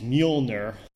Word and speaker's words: Mjölnir: And the name is Mule-nir. I looked up a video on YouTube that said it, and that's how I got the pronunciation. – Of course you did. – Yep. And that's spelt M-J-O, and then the O technically has an umlaut, but M-J-O-L-N-I-R.